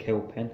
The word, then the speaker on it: Kilpin: been working on uh, Herbert Kilpin. I think we pronounced that right. – Yes, yes. And you've